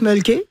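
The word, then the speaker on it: Mölkky: On prononce Mulky. – Ouais. – Et c'est M-O-L-K-K-Y.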